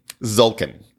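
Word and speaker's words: Tzolk'in: Zoltskin? – That's Zoltskin? – Zoltskin? – Zolkin. – Zolkin. – Yep.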